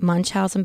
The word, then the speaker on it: Munchausen: It's about, I'm going to butcher the name, but Munchausen by Proxy.